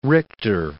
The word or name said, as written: Richter